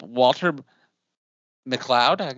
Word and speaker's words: Walter McLeod: Today, I have the great honor of having visit me, the first person who interviewed me, Bishop Walter McLeod.